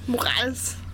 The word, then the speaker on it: Morales: Morales. – Ah, das ist ja eigentlich die richtige Aussprecherweise, ne? weil die, seine Mutter ist, oh je, Puerto Rican.